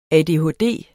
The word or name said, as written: ADHD